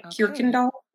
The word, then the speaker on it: Kuykendall: Yes, it is Dutch in origin. And apparently UY, that combination in Dutch is pronounced ear. So I think it's more like Kuykendall when you're in Dutch speaking countries.